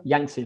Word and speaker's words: Yangtze: Yangtze.